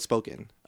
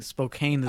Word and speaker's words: Spokane: Over there they call it Spokane. – Oh, so I found that out. – So I've been saying Spokane this whole time. – I thought so too, because it's spelled with an E at the end, right? Like Spokane. – Yeah, Spokane. – But they say Spokane, and I'm like, all right, cool.